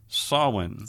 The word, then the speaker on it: Samhain: but that's not the way you really say it. It's pronounced Samhain.